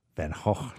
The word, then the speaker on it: Van Gogh: You would go a couple blocks away and see the beloved Van Gogh. Van Gogh, thank you very much. The Dutch pronunciation of Vincent.